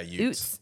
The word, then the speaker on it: Utes: Yeah, Utes.